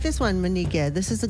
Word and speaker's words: mineekeh: And this is spelled M-I-N-E-E-K-E-H, but that K sounds like a G. – Yeah, it sounds kind of like a G, but it's more so like a really soft K. So if I say Miniga. – Miniga. – Yeah, so it's not so much like a hard G. It's kind of like a mix.